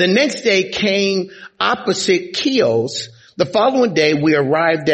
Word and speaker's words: Chios: It's pronounced Chios.